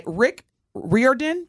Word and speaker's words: Rick Riordan: And it is adapted from the popular book, The Lightning Thief, by Rick Riordan. – Yeah. – I said that right? – You did.